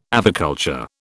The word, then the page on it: aviculture: British English